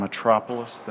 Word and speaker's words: Metropoulos: My name is John Metropoulos. That's spelled M-E-T-R-O-P-O-U-L-O-S.